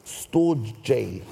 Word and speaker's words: storge: There's one called storge, spelled S-T-O-R-G-E. Storge, it's pronounced storge.